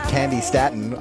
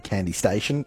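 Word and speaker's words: Candi Staton: I said it right the first time. If you know me over the years, in our database, it actually has station on it. And so every time I read it out, I've always read Candy Station.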